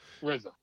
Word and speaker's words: RZA: RZA, or RZA, I guess is RZA. How you pronounce it. RZA? – RZA, yes.